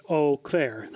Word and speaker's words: Eau Claire: I always thought it was like Eau Claire or something like that. And it's actually just Eau Claire. Like Eau Claire.